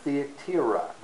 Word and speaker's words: Thyatira: Among the churches, the cities where the churches of Revelation are located, are Pergamum, and Thyatira. That's the correct pronunciation.